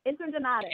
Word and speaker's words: Interdonato: It's called Interdonato.